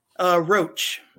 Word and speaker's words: Roche: Roach. It's spelled R-O-C-H-E.